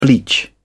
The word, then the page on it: bleach: British English